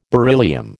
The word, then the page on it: beryllium: American English